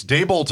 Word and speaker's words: Daboll: Can I get an official pronunciation on Brian Daboll? Daboll? Dabble? – It's Daboll to me, but Daboll. I do hear it different.